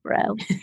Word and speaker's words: Braud: It's B R A U D but pronounced without the D, bro.